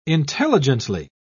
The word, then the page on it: intelligently: British English